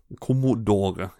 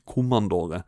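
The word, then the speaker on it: Commodore: Kommodore heter det, ikke Kommandore.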